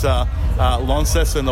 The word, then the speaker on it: Launceston: And I think since I pronounce Launceston the way it's meant to be pronounced, I'm now an adopted Tasmanian.